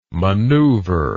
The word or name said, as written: manoeuvre